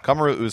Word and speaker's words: Kamaru: Kamaru Usman. – You said it wrong again. – How do you say it? – You got me messed up. Yeah. Kamaru. – Huh? – Kamaru.